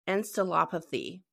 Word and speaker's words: encephalopathy: Is called chronic traumatic encephalopathy.